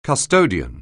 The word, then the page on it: custodian: anglais